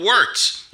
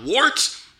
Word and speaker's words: Wort: It is The Wort. Not wart, not fort, not short. The Wort.